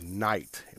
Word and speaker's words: Nigght: I don't know, a day or two ago, whatever, but they're called Night, but they spell it NIGGHT.